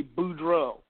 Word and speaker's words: Boudreaux: That's pretty close. It's going to be Boudreaux.